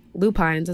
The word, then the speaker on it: lupines: And they're called, I think, lupines is the correct pronunciation.